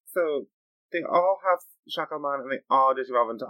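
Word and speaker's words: Shakkoumon: They jump into action with Sayakomon. – I thought it was Shakomon. – Shakomon. It's spelled like Sayakomon. Pronounced like Shakomon.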